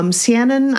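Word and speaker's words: Seanan: And I am now reading Siannon, I think that's how it's pronounced, Siannon McGuire's book, Adrift in Currents Clean and Clear.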